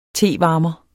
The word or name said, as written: TEVARMERE